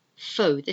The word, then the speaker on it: faux: Faux, this is taken from the French word, which means false, F-A-U-X. You could say it's faux leather. It's not real leather, it's false leather. Okay, faux.